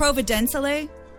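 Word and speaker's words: Providenciales: Is it Providenciale? – Yes, thank you.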